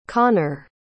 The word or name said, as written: konner